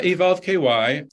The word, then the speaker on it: Evolve KY: So maybe you can tell us a little bit more about the founding of Evolve Kentucky, or I don't know if you say it like that, or Evolve KY is how it's written. – Yeah, Evolve KY.